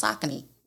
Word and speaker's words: Saucony: Like how we say Saucony, I was working for City Sports and was like, oh, this shit is pronounced Saucony. – That's how it's pronounced. Saucony. Yes.